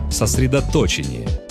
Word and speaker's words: сосредоточение: Есть слова, которые произносят неправильно почти все. Сосредоточение. Одно из них. Да, именно так. С ударением на третью «о». Вне зависимости от того, о чем идет речь. О сосредоточении интеллектуальных сил или сосредоточении войск. Никакие другие ударения тут не допускаются.